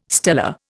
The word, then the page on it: stellar: Indian